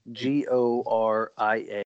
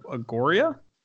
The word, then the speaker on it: Chiss Goria: So scale of CHISS - GORIA. – How do you spell that? What? CHISS? – Yes. - GORIA. – Oh, boy. What is it? Scale of Agoria? – Chiss Goria.